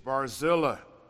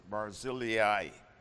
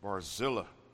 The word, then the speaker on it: Barzillai: His name is Barzillai. Some of you may pronounce it Barzillai. I'm comfortable with Barzillai, according to the Hebrew.